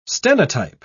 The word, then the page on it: stenotype: British English